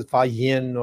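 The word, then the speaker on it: Faxian: So one was Fa Xian, which the Indians pronounce as Fa Yin or something like that.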